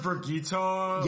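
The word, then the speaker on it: Birgitte: Okay, how do you say her name? – I say Birgit. – Birgit. That's how I said Birgit. Birgit is how I pronounce it. – Birgit. Okay, well that's either that's a lot more. – I've heard Birgitta.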